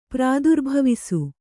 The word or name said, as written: ಪ್ರಾದುರ್ಭವಿಸು